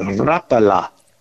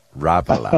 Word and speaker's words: Rapala: And boy, I mean, she said, no. You pronounce that Rapala. – Rapala.